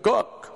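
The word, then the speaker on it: Cooke: Spells his name Cock, but pronounced it Cook.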